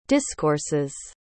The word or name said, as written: discourses